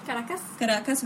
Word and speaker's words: Caracas: Caracas,